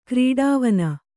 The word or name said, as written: ಕ್ರೀಡಾವನ